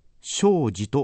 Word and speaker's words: 生死: ところで、仏教では生、すなわち生きることと死ということを生死と書いて生死と読みます。